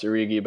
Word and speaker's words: Origi: Uh, it is pronounced Origi, by the way. – Oh, okay, um, Origi.